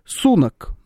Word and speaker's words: Сунак: Ну так вот, а сейчас там Риши Сунак, напоминаю, ударение именно так.